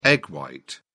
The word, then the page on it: egg white: British English